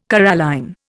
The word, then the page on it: coralline: Indian